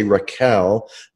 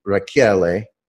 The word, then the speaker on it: Rachiele: And everyone mispronounces it, including my family. We say Raquel, but it's Italian. It should be Raquele.